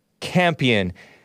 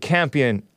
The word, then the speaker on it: Campion: This is the CNN headline, Director Jane Campion, it's spelled like champion, but no H, Campion, I presume that's how we pronounce it, apologizes for thoughtless, her word, comment about Venus and Serena Williams.